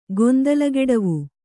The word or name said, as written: ಗೊಂದಲಗೆಡವು